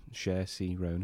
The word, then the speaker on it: Saoirse Ronan: Saoirse Ronan, is that how you say her name? – Yes.